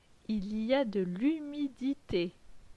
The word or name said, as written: Il y a de l'humidite